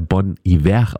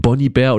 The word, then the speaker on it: Bon Iver: dass er Bon Iver ausgesprochen wird und nicht Bonnie Bear oder Bon Iver, wie viele damals ja immer noch annahmen. Und hier hören wir aus dieser zweiten Platte Bon Iver mit Holocene.